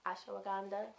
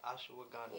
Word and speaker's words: ashwagandha: Ashwagandha. – Ashwagandha.